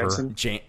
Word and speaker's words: Janssen: Famke Janssen, how do you say her name? – Whatever. – Janssen. – Janssen. – Famke Janssen.